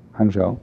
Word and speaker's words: Hangzhou: Back in Hangzhou, how do you pronounce that? Hangzhou.